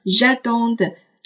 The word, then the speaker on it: j'attends: 第二，在单数的时候，很多同学会读成 j'attends, tu attends。请注意，在单数的时候，三个人称末尾的字母 they 是不发音的，加了 s 也仍然不发音，千万不要读成 j'adon 的，它是 j'adon j'adon。